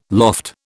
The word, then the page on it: loft: British English